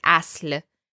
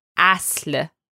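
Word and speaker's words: asl (اصل): Next, the word asl. Now, this is one of those Persian words that's a little more difficult to pronounce. The s and the le sound are right next to each other. But again, it's asl.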